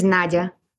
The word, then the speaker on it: Nadya: So my first name is Nadya, and my last name is Zhexembayeva.